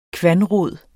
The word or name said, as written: kvanrod